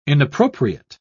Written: inappropriate

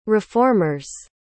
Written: reformers

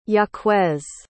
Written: jaquez

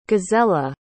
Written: gazella

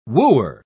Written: wooer

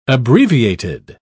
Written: abbreviated

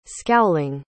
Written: scowling

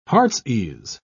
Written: heartsease

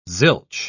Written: zilch

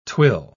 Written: twill

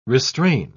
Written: restrain

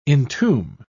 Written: entomb

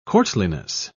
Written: courtliness

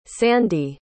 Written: sandie

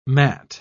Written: mat

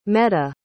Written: meda

